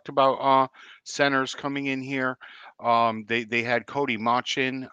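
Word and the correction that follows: Mauch: Did I pronounce that right? Mosh? – Yeah, the kid from North Dakota State in.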